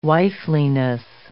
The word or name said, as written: wifeliness